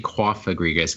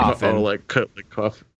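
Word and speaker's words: Cofagrigus: It's actually Kofagrigus because it's like a coffin. – Kofagrigus.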